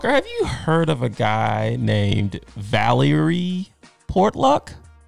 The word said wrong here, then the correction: Portlock: Valerie, V-A-L-I-E-R-Y. I'm probably pronounced that wrong. Portluck. Portluck. – I have not. – All right. Or Portlock, I should say.